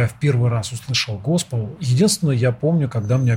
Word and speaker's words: госпел: Возникает желание сразу задать вопрос о таком направлении творчества вашего, да, как госпел, да, это правильное ударение? – Правильно, абсолютно.